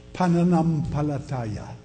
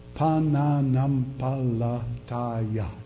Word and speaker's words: Pananampalataya: And this account that we have of faith in Tagalog is 7 syllables. Aren't you glad you don't have to learn that language? Pananampalataya. Pananampalataya. 7 syllables.